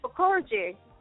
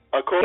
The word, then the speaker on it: Okoroji: Bless it's fine. It's Okoroji. Okorji. Can you hear me? Okorji.